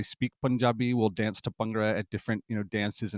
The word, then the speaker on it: Bhangra: And how do you say it? Bhangra? – Bhangra. – Bhangra. You got to say Bhangra. – Bhangra. Yeah, I mutilated. I was not born speaking Punjabi. I didn't, you know, start encountering Punjabi words until I was almost 30. So, you know, I'm still doing my best. Aspirated syllables can be very tricky.